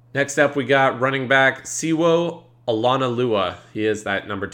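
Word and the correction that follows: Sewo: I believe it's Shewo. There's a silent H in there. Shewo.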